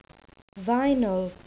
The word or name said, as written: vinyl